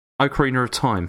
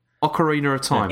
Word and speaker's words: Ocarina of Time: Similar to Akami, Ocarina of Time. Is no. that right? – No. – How do I say it? Say it again. – Ocarina of Time.